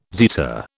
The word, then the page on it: zeta: British English